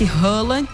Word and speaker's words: Haaland: Tem um bom jogador, que é esse Haaland, que se pronuncia dessa forma,